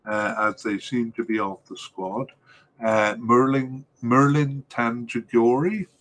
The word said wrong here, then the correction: Merlin Tanjagora: And I'll say that again because I said it wrong. Merlin Tanjagora last appeared for the national team as a starter in the 2017 World Cup.